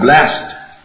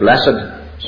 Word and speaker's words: blessed: You say, he blessed someone, or I feel blessed. The adjective, sometimes we pronounce it blessed, sometimes we pronounce it blessed. Both are right.